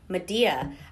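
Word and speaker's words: Medea: Also, Milliken's School of Theater and Dance is putting on opening weekend of Madea. I did have to ask someone at Milliken if it was pronounced the same way as Tyler Perry's franchise, Madea. She said yes. Same yeah. pronunciation, different topic.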